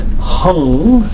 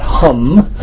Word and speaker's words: hung: His seed syllable is hung, H-U-N-G, but the Indians would pronounce that hum, so take your pick, but the Tibetans all mispronounce things, so there's all these amazing Sanskrit stuff, and then they mispronounce it, and it works anyway.